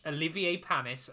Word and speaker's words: Olivier Panis: are going to watch Olivier Panis, and I did make sure to say his name correctly.